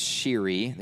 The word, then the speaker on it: Shire: The Shire, it's pronounced Shiri, the Shiri Highlands.